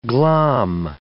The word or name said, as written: glom